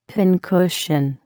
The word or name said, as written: pincushion